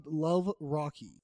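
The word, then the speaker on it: Luvh Rakhe: It's spelled L-U-V-H. – Oh. – And his last name is R-A-K-H-E. But I Googled how to pronounce that. – Oh, okay. – And I came up with Love Rocky.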